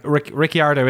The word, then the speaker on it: Ricciardo: Well, it's a way not to get down the Pierre Gasly or the Charles Leclerc, Charles Leclerc thing, but like Ricciardo is how the Italians pronounce it, and also he kind of says it. He almost doesn't say either Ricciardo or Ricciardo. He kind of does a little inflection on the I, like he's in the middle somewhere.